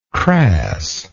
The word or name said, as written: crassness